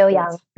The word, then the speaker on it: Xiu Yan: And do you pronounce it Xiu Yan? Am I right? – Yes, exactly. Yeah. Xiu Yan. Yep.